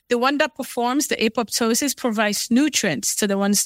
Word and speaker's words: apoptosis: So coming back to this, you talked about, can you pronounce it again? Is it apoptosis? – Apoptosis.